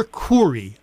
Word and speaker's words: Khouri: Just FYI, my last name is actually spelled, and this is the first time I've done this on the radio, K-H-O-U-R-I. So it's pronounced... It's more Corey, actually. I remember one time I told someone in front of my mother that it's like Coors beer.